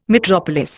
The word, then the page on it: metropolis: Indian